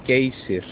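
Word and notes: Geysir: Icelandic

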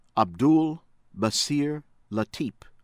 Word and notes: Abdul Basir Latip: American English; Philippines

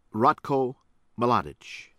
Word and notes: Ratko Mladic: American English

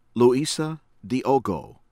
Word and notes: Luisa Diogo: American English; Mozambique

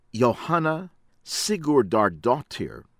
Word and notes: Johanna Sigurdardottir: American English; from Iceland

